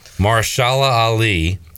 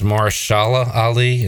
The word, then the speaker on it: Mahershala Ali: I think it's Mahershala Ali is how you say it.